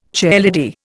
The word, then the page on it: chairlady: Indian